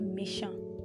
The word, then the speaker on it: méchant: Now, you can see that there is a difference between the pronunciation of the word. Yeah, that's actually because when you're writing or spelling the word méchant for that of the male, you spell it this way, M-E-C-H-A-N-T, and then that of the female is spelled M-E-C-H-A-N-T-E. Okay, that's what actually makes the pronunciation differ.